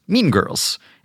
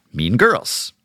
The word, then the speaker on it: Mean Girls: For example, the Lindsay Lohan film about a cruel female clique is pronounced Mean Girls and not Mean Girls.